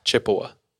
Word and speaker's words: Chippewa: I'm going to do my best to pronounce it, but it's C H I P P E W A. Chippewa? Chippewa? Chippewa. Chippewa. – Sure. Chippewa, yeah.